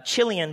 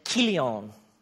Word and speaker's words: Chilion: And the name of his wife was Naomi, and the name of his two sons was, Mahlon and Chilion, but it's actually pronounced Mahlon and Kilion. Just sounds better in Hebrew.